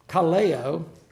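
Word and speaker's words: kaleo (καλέω): There's a Greek word, kaleo, is the way it's pronounced, that is used again and again in this passage.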